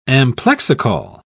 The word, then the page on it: amplexicaul: British English